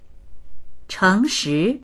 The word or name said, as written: cheng2shi2